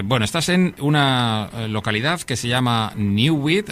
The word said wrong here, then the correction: Neuwied: no sé si lo he dicho bien, o Neuwied, ¿cómo se dice en alemán? – Neuwied. – Vaya, no acierto una.